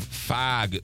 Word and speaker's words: fog: From the Country Club Car Wash text line, hope it goes well today, but I can't listen for another 4 hours of fog being mispronounced with an A. Why do you people say fog wrong? – Oh, man. – Fog. – That's it. There you go.